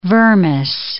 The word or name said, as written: vermis